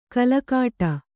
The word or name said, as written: ಕಲಕಾಟ